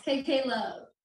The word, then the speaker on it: KK Love: Yes, KK Love.